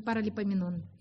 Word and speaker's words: Паралипоменон: Но когда ты, знаете, предупрежден, вооружен, открываем вторая книга Параполеменон. Вторая книга Параполеменон, 20 глава. Я неправильно делаю ударение? А я что сказала? Параполеменон. Ай, извините, Паралипоменон.